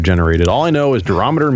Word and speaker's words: durometer: Durometer 88. – Oh, okay, cool. – Durometer. – Yeah, you said it. You said it right. Durometer.